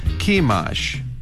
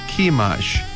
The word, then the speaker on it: Chemosh: Sihon, S-I-H-O-N, Sihon 이렇게 발음해주시고 그모스는 C-H-E-M-O-S-H입니다. 그래서 Chemosh, 한번 더요. Chemosh, 그모스를 Chemosh 이렇게 연습해 주시기 바랍니다.